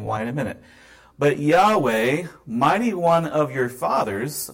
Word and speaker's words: Yahweh: Well, in Hebrew, since the verb form tells us the correct pronunciation, to discover the correct pronunciation of Yahweh's name, we only need to figure out what verb form is being expressed with the word Yod-Heh, Wow-Heh, the four letters in Hebrew, which I believe is pronounced Yahweh.